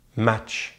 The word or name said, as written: match